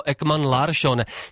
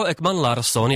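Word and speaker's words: Ekman-Larsson: Komentátoři České televize jeho jméno vyslovovali jako Ekman Larsson, nikoliv jako Ekman Larsson, jak jsme mohli být zvyklí z minulosti. Jak ale zjistili kolegové ze zpravodajského serveru iDnes.cz, výslovnost s eš je přesnější.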